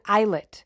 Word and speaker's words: islet: One, I need to humbly correct myself because I was pronouncing I-S-L-E-T, I was pronouncing that word like a idiot, and I was pronouncing the S. But guess what? I looked it up, and it's totally not pronounced with the S. So I'm sure half of you are like, yeah, P-A-K, I definitely knew that. Yeah, well, it left my brain. So we're going to pronounce that word islet. So again, islet. All those times I pronounced it wrong.